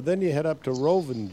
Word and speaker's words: Rovinj: Rovinj. – So we call this Rovinj. – Okay. – Right. Almost like revenge.